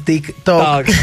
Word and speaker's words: TikTok: Entonces, a veces en una reunión eso dije Tito. Y yo, no mentiras, TikTok.